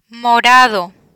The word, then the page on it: morado: Spanish